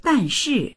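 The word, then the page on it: dan4shi4: Mandarin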